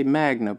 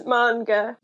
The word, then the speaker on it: manga: I say Magna, but it's not Magna. – It's manga.